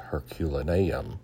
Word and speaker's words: Herculaneum: I pronounce it Heraclium, but I think it's Herculaneum, an ancient city at Mount Vesuvius' foot.